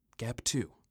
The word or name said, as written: Gap 2